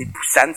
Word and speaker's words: Busan: Busan, Le U se prononce ou. – Voilà, et il me dit, c'est Busan, c'est pas Busan, c'est pas Busan.